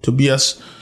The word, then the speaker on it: Tobias: There's a young actor named John O'Davis, played Tobias or Tobias, which is how you would pronounce that in German, Tobias.